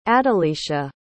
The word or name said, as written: adalicia